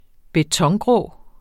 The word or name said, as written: betongrå